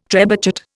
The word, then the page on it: trebuchet: Indian